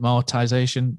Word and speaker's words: amortization: Is it amortization? You help me out. You're a CEO. I can't even speak. – Amortization, isn't it? – Amortization. Yeah. That means it gets drip fed.